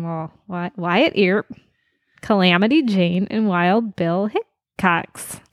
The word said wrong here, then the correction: Hickok: Hickok. – Hickok. – Yeah. – No plural.